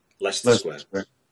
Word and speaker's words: Leicester Square: at the View Cinema in, I'm going to mispronounce it, Leicester Square, second time you got it. Leicester Square. Yeah, we like to put letters in that make it confusing.